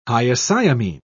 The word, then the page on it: hyoscyamine: British English